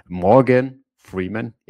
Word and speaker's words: Morgan Freeman: Morgan Freeman, jetzt habe ich ihn, genau. So wird er richtig ausgesprochen.